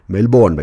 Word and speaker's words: Melbourne: You got to say that properly. Melbourne, not Melbourne. Melbourne, like Bourbon biscuit.